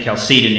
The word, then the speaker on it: Chalcedon: Well, let's look briefly at what the Council of Chalcedon did, sometimes referred to as Chalcedon. I think Chalcedon is the preferred pronunciation.